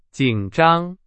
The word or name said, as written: 紧张